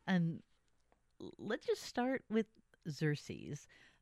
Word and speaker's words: Xerces: And today I am joined by Matthew Shepherd, a communications director for Xerces. Did I say it right, Matthew? – You did, yeah.